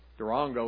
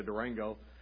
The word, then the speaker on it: Durango: Durango is how they say it. We say Durango.